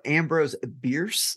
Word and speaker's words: Ambrose Bierce: And so Ambrose Bierce, is that how you pronounce it? – Yes, sir.